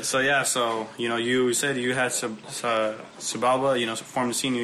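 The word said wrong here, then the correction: Xibalba: How do you pronounce it? Shibalba.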